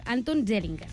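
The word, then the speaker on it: Anton Zeilinger: Són el francès, a veure si ho diem bé, Alan Aspect, el nord-americà John Clauser i l'austríac Anton Zellinger. – Molt bé aquesta pronunciació.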